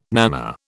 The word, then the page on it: nana: British English